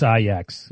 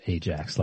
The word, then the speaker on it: Ajax: It's Ajax, if you want to get it right. – Oh, is it Ajax? – It's not Ajax.